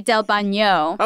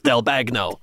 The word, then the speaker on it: Bagno: Also, the letters G-N-O are pronounced Gno, so it'd be Del Bagno.